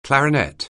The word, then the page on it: clarinet: anglais